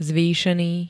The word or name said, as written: zvýšený